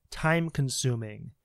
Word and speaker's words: time-consuming: So time is easy, but when you get to consuming, so the stress is on syllable two, time consuming, time consuming. So you see time and sum, the second syllable, and consuming, time consuming. Time and sum have the stress, time consuming.